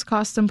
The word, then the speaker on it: Costum: There are a couple of things Costum Plummer leads with when he meets people these days. One, that his name rhymes with Boston. – Costum. You like Costum? The city, Boston. Costum.